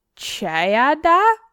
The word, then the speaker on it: Ceadda: It's a masculine given name of Anglo-Saxon origins, the modernized form of the old English given name Chada. – Chada. – Chada? It's spelled Chada cheese. It's spelled C-E-A-D-D-A.